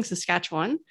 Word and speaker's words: Saskatchewan: So today we're talking to Mariah Vargo, who's joining us today from Kipling, Saskatchewan. I always mispronounce that, don't I? How do you say Saskatchewan the real way? If you live I think there, you, I think you did it right.